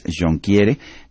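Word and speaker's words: Jonquière: Tenemos en la línea telefónica al doctor Rob, no sé cómo pronunciarlo, imagino que es Jonquiere, pero ya nos lo dirá él. Él es director ejecutivo de la NVVE. Doctor Jonquiere, ¿la pronunciación es correcta? I'm sorry, Dr. Jonquiere. How do I pronounce your name? – Jonquiere is the right way. Jonquiere. – The French way.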